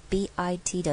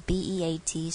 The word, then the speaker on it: beat: 再来 heartbeat 这个地方容易搞混，容易做不好的是这个 beat， EA 这边也是一个很标准的长母音 E 的组合，所以请不要念成 heartbeat heartbeat，不要变成了 BIT 的 bit，它这边是 BEAT 是念 beat，嘴型要拉开要微笑的那个 E。